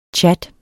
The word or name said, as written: CHATTEN